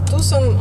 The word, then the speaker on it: Тусон: Или Тусон, как вы произносите? – Тусон.